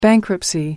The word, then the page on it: bankruptcy: American English